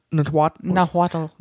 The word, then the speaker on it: Nahuatl: So the word avocado comes from the Spanish word, which in turn comes from the, I don't know how to pronounce Nahuatl. – It. Nahuatl. Nahuatl. Okay.